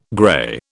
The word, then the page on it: grey: British English